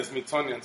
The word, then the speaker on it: Smithsonian: Smithsonian, sí.